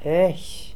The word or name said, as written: ekh